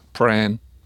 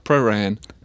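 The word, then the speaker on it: Prahran: Allora, in inglese dovrebbe essere, per quelli che sono di questa città, è una sillaba, anche se si scrive con due, e si dice qua pran. – Pran, una sillaba. – Con la A lunga. – Giusto, pran. Però tecnicamente dovrebbe essere una cosa tipo praran.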